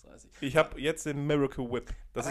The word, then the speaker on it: Miracle Whip: Jo, Alter, Miracle Whip wird das in Deutschland ausgesprochen und es das heißt einfach Miracle, Miracle Whip.